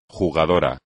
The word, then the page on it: jugador: Spanish